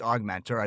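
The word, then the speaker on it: Ogmentor: So you'll see in the show title and the show notes, Augmentor is spelled O-G. Mentor, pronounced Augmentor.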